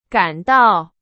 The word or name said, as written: 赶到